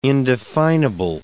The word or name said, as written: indefinable